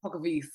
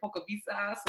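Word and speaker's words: Polkowice: Pokavice. So Pokavice. W's in Poland are a V. Don't worry, girl, because I think I say it wrong too, but I know that the W is a V.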